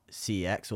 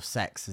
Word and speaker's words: CeX: As much as, sure, when you were a kid and there wasn't really CEX or sex as it is pronounced, but no one does CEX.